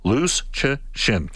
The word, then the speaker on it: Lucician: And the phonetic pronunciation, Lucician, coming down the pipe.